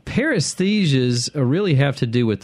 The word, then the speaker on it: paresthesia: It's P-A-R-E-S-T-H-E-S-I-A. – Paresthesias.